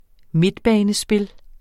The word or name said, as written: midtbanespil